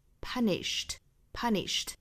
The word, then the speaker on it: punished: 后面我们来看 punish 这个单词，它加了 ed 之后，尾部的这个音应该是读成清辅音的 t 的发音。 Punished. 大家不要看到字母 d 就把它读成那个浊音的 d，应该是对应的是 t。